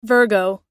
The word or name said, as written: virgo